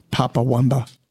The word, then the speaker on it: Papa Wemba: That was Manuel Turizo from Ecuador and Papa Wemba from Democratic Republic of Congo. Good songs. – Papa Wemba.